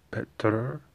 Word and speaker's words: Petr: Now, for Jan, the Czech pronunciation is Petr. But he's from Russia, so I guess it's different. He's from Krasnoyarsk, Krayea, Russia. So, I don't know, maybe it's pronounced differently, but as far as I know, it's Petra, as far as I know.